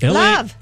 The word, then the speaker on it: Lauv: Love. – It is pronounced love. – Love. – Oh, L-A-U-V. – L-A-U-V.